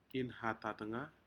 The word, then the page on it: inhatatga: Aneityum